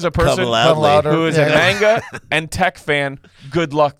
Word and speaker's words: manga: Who is who is a magna? Magna? Am I saying it right? Why do I feel like I'm not? – Magna cum laude? – Magna. – Manga. – Manga. – Manga. – Manga. – Oh, yeah. Manga.